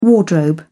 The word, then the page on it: wardrobe: British English